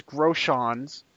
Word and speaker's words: Groshans: And you made sure of the correct pronunciation of his last name. It is Groshans.